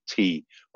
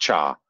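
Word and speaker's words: te (茶): There's so in some parts of the country, it's called te, which has given us the English word tea. And in other parts of the country, it's pronounced cha, which in slang in England, we can, you know, cup of cha is also the same for cup of tea.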